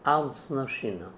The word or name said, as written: Almznoshinoo